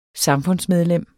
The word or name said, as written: samfundsmedlem